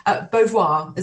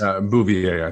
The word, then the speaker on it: Bouvier: Beauvoir, is that correct? – Bouvier, I think, is how Bouvier. She would pronounce it.